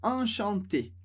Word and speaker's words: enchanté: Então, a maneira de dizer é exatamente a mesma, mas na hora de escrever a gente acrescenta um E. Mas a pronúncia é sempre enchanté, homem ou mulher, vamos dizer enchanté.